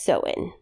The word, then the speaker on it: Samhain: So Halloween dates back to an ancient Celtic festival called Samhain, which is spelled Samhain, but it's pronounced Samhain.